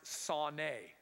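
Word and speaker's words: sauné (שנא): It's read right to left and pronounced sauné. That's a S sound on the right, N in the middle, and then like an eh sound at the end.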